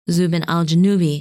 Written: Zubenelgenubi

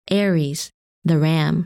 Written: Aries

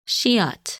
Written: Scheat

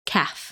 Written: Caph

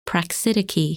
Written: Praxidike

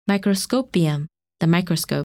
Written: Microscopium

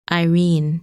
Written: Irene

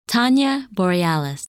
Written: Tania Borealis

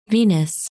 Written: Venus

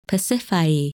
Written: Pasiphae

